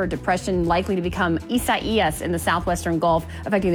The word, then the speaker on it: Isaias: Isaias, but thank Ichabod's you so better.